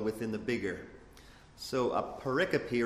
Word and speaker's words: pericope: And the word was pericope. I always thought that's how it was pronounced. Then I went back and realized it's a Greek word, and it is actually pronounced pericope.